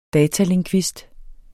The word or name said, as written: datalingvist